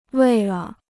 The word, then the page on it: 为了: wèi le